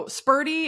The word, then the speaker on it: Sperti: So Spurdy, it's spelled Sperti.